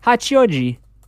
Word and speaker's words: Hachioji: He then drove the car down the National Highway number 16 towards Hachioji Hachioji. Oh my, that's a hard word to say. Oh, I said it right. Hachioji.